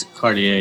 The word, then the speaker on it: Cartier: It's Cartier, yeah.